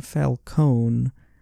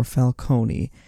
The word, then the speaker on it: Falcone: They are kind of inconsistent on the pronunciation of that name. They generally fall on it being Falcone, but you do get the occasional Falcone with that kind of hard Y slip on in there.